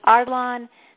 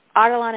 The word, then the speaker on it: Ardalan: My name is Shadi Ardalan. That's S-H-A-D-E-E. Ardalan is spelled A-R-D-A-L-N. A-R-D-A-L-A-N.